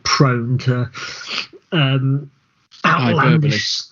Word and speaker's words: hyperbole: Yeah, I was going to say hyperbole, but I wasn't sure whether I was going to pronounce it right or not, because for many years I used to pronounce it how hyperbole.